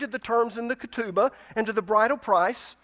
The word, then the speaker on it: ketubah: It began with what was called the ketubah. Let me pronounce that for you again. Ketubah, K-E-T-U-B-A-H. The ketubah was like a prenuptial agreement.